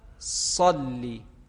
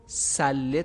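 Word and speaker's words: صلِّ: یا در ذکر تشهد داریم اللهم صلی صلی. باید مراقب باشن نمازگزاران که صلی رو سله تلفظ نکنن، چون معنای کلمه عوض میشه.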